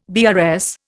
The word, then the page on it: BRS: Indian